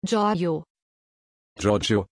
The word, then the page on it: Giorgio: German